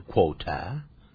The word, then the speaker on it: quarter: 如果是英式英文，它念 quarter，是这样的哈，它不卷舌的念成英式英文 quarter。